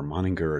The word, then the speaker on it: Moninger: Is it Moninger or Moninger? Hard G or soft G? It was the former. Moninger, it's a hard G.